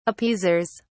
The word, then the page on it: appeasers: British English